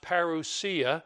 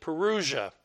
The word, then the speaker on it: parousia: The word present there is the word, well, in Greek it's pronounced parousia. We usually pronounce it parousia.